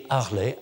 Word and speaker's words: Harley: Euh, le titre de l'ouvrage, comme je l'ai dit, change selon la copiste, mais le manuscrit Harley, je prononce bien le H, le manuscrit Harley à Londres,